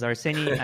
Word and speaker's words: Arseniy: Yeah, as you pronounced my name correctly, my name is Arseniy.